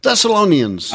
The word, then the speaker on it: Thessalonians: Thessalonians, for those of you that need the correct pronunciation.